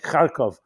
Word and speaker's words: Kharkov: Anyone that, cities like Zhytomyr, cities like Kharkov. I'm pronouncing it in the Yiddish and the Jewish way. I know their names have been somewhat changed pronunciation, but we're all familiar with them.